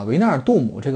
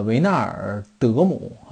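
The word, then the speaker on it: Wijnaldum: 呃，另外一点，如果大家较真儿的话啊，维纳尔杜姆这个发音翻译反而不是特别啊，不是特别这个合适啊。我刚才查了一下。呃，维纳尔德姆啊，可能更更贴切一点，就贴切荷兰语。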